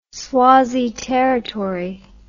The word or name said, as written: swazi territory